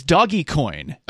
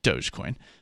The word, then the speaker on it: Dogecoin: What is Doggy Coin? He asked. Woof. Which is Dogecoin but he mispronounced it, I guess.